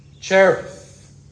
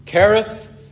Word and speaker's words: Cherith: the brook Cherith, or as some pronounce it, Kerith. And I'm not going to go into the reasons why sometimes this particular Hebrew letter is transliterated a CH or a K.